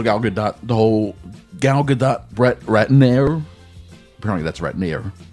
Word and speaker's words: Gadot: It's Gadot.